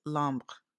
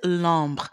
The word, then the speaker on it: Lombre: I don't know if they Alexis Lombre. I think I'm pronouncing it like it's French.